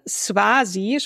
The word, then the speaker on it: Swazi: Swazi spricht man ungefähr genauso aus. Erste Betonung auf Swa und dann Si und Kosa.